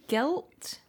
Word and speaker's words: GYLT: I want to say Guilt. – I believe that's how it's pronounced. – Which is spelt G-Y-L-T.